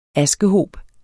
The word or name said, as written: askehob